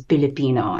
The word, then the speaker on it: Pilipino: Um, but I did want to honor sort of our, our natural Tagalog way of pronouncing that, which is Pilipino, and sort of just um, pay tribute to um, our heritage in that way.